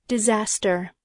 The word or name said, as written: disaster